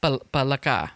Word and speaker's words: palaka: Is it palaka? Palaka.